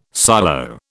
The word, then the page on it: silo: British English